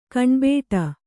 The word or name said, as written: ಕಣ್ಬಿಡು